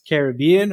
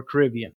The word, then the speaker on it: Caribbean: and that is whether it's pronounced Caribbean or Caribbean. – I think that's one that can really go either way. I've always grown up saying Caribbean, but I think it's one of those that with usage over time, people pronounce it however they want to pronounce it.